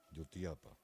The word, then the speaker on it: Jutiapa: Vamos a orar mucho por todos los hermanos de Jutiapa. Es así como se pronuncia, ¿verdad? Jutiapa. Para que... Oh, Jutiapa. Jutiapa, ya.